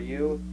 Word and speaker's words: ewe: Well, it's spelled E-W-E. How would you pronounce it? I'm interested. – Eel? – Yo or you? – E or E. – You? Yo? – You. – You, okay. You.